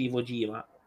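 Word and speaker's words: Iwo Jima: Lettere da Ivo Gima, credo si mm-hmm. pronunci Ivo Gima. Sì, è giusto, corretto.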